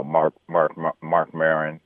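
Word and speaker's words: Marc Maron: Everybody now, especially at a place like the Comedy Store, you know, you got Bill Burr, you have Mark Maron.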